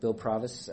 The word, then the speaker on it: Provis: Short, just think short O. – All right, this is the second session with Bill Provis.